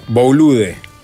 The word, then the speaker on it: Boulud: Sí, se dice en francés. O sea que yo lo dije mal, capaz. – No. – Pero se escribe B larga O-U-L-O-U-D. – Boulude. Boulude. – Pero da. – Boulud. – Eso, exactamente, gracias.